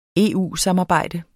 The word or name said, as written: EU-samarbejde